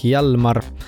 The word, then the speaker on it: Hjalmar: Ja selleks on Islandi reggae hit bänd nimega Hjalmar. Väga hea meelde, et peaaegu nagu Jalmar, aga hash on veel ees, nii et Jalmar.